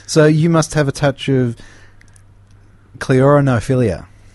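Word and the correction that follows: ceraunophilia: Is that how you would pronounce that word, Kevin? – Again? – Serranophilia? – Serranophilia. Serranophilia. Sounds a bit lighter.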